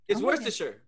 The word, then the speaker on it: Worcestershire: It's Worcestershire.